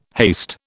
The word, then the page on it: haste: British English